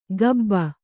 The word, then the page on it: ಗಬ್ಬ: kannada